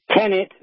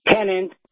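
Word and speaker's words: tenet: It is, the word is tenant, not tenant. The word is tenant, not tenant.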